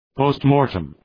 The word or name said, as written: postmortem